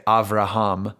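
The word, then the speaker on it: Avraham (אברהם): So like we say Abraham, but the Hebrews, like he would not have recognized his name exactly. They would say Avraham.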